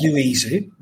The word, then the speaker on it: Loizou: Very brave and almost successful attempt at pronouncing my surname, which is Loizou. But no one's really got that right over the years. And that includes me, I believe.